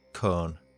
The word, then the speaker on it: Colne: Cone is spelt C-O-L-N-E, but we pronounce it Cone.